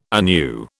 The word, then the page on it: anew: British English